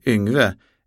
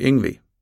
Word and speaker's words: Yngwie: Som ni kommer att märka uttalar jag inte hans namn helt konsekvent i denna ljudbok. Detta beror på att många i Sverige fortfarande säger Yngve även efter det att han har bytt namn till Yngwie, men lika många säger också Yngwie.